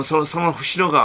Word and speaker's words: 伏野: その伏野川があって。 – はい、これ伏野って読むんですか？ – そう、伏野って読むんですよ。